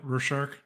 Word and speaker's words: Rorschach: And I think this game is like a Rorschach test. Is that how you say it? – Yeah, Rorschach.